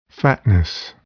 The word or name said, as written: fatness